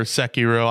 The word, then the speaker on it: Sekiro: Sekiro or Sekiro. I say Sekiro because that's what it sounds like they say.